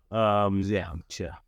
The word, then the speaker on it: Zercher: Zercher.